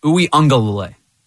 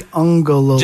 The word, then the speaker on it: Uiagalelei: The quarterback for Clemson, his last name is Uiagalelei. That is how you pronounce it. It's not as hard as it may seem to you. That is how you pronounce his last name.